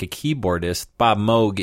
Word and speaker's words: Moog: John is saying it correctly. It's M-O-O-G. It's about Bob Moog. It's not Moog. It's Moog. That's actually correct.